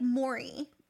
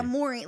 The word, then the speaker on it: Morey: It's like Morey. Like a Morey, like money. Like money.